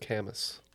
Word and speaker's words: Camus: And it is Albert Camus, philosopher. – Oh, thank God. – Number seven, this one. – It's pronounced Camus.